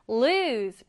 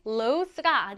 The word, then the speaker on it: lose: 자, 이어서 오늘 또 많이 나오는 단어 중 하나인 lose 보겠습니다. 발음하실 때는 lose가 아니고요. lose 이렇게 발음합니다.